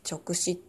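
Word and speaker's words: 勅旨: 直視もそれなんですけど、あの、束、束辺に力って書いて、うまいっていう字を書いて直視って読むんですけど、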